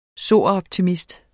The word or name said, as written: soroptimist